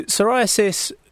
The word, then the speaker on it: psoriasis: Yeah, so yeah, it's a silent P. So when you say it, otherwise, yeah, it can be quite complicated if you put that one in as well. So psoriasis, so we might have seen people with psoriasis, and sometimes we might mistake it for eczema.